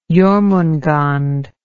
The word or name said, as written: Jormungand